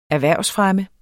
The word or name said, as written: erhvervsfremme